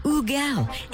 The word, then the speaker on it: Hugel: His name is spelled H-U-G-E-L. I would automatically go to call him Hugel, which he's fine with. Technically, the correct pronunciation is Ugel.